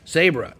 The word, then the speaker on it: Sabra: Sabra. – Sabra. Spell that. – S-A-B-R-A.